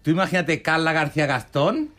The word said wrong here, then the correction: Gascón: Gascón. – ¿Cómo se dice? – Gastón era el de La Bella y la Bestia. Gascón. – Gascón.